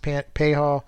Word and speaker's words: Pages: Looks like Pages, P A G E S, but it is pronounced Payhall.